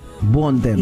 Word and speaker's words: Bontempo: Bontempo. – Y muchos escriben Bom. – Claro, porque la pronunciación es bon tempo, rápida.